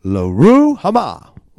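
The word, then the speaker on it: Lo-ruhamah: So, Luhurma. I didn't say it right again. It's Loruhama. Loruhama.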